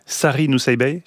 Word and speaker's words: Sari Nusseibeh: Sari Nusseibeh, c'est comme ça qu'on prononce ? – Oui.